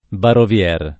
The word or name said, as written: Barovier